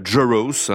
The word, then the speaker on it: Jarosz: Il s'agit d'une jeune chanteuse qui a grandi au Texas et qui s'appelle Sarah Jaros, hein, c'est un nom d'origine polonaise, ça s'écrit J-A-R-O-S-Z.